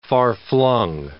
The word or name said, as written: far-flung